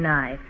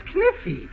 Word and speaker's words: knife: Class, I will spell the word K-N-I-F-E. – That's kniffy. – No, the word is knife. K is the silent letter. K is always silent in front of N.